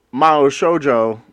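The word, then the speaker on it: Mao Shoujo (魔法少女): Phonetically would be spelt M-A-H-O space S-H-O-J-O. The way they're saying it in the show, the Japanese pun is M-A-S-U and then shoujo.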